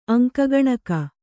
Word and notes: ಅಂಕಗಣಕ: aŋka gaṇaka

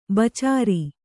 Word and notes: ಬೆಚ್ಚರ: beccara

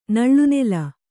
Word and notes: ನಳ್ಳುನೆಲ: Kannada

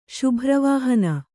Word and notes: ಶುಭ್ರವಾಹನ: kannada

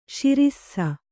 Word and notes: ಶಿರಿಸ್ಸ: śirissa

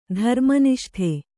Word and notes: ಧರ್ಮನಿಷ್ಠೆ: dharma niṣṭhe

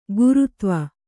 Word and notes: ಗೌರೀಧವ: gaurī dhava

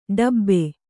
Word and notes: ಡಬ್ಬಿ: ḍabbi

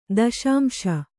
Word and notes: ದಶಾಂಶ: daśamśa

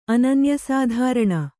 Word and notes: ಅನನ್ಯಸಾಧಾರಣ: ananyasādhārāṇa

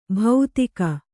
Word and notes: ಭೌತಿಕ: bhautaika

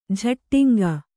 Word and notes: ಝಟ್ಟಿಂಗ: jhaṭṭiŋga

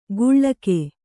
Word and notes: ಗುಳ್ಳಕೆ: guḷḷake

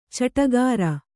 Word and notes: ಚಟಗಾರ: caṭagāra

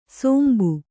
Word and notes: ಸೋಂಬು: sōmbu

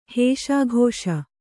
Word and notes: ಹಸುಗೂಸು: Kannada

